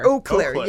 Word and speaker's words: Eau Claire: I think it's Eau Claire. – Eau Claire, yes.